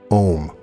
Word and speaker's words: Om: It is a sound which you can spell as Om or Aum. It is pronounced like home, only without the H.